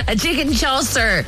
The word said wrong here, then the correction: chasseur: How do you pronounce it? – Chasseur. Chicken chasseur. It's a French sauce.